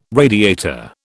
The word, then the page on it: radiator: British English